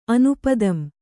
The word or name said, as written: ಅನುಪದಂ